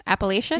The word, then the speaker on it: Appalachian: Appalachian? – Did you know that it's actually pronounced, I learned this on My Favorite Murder, it's pronounced Appalachian.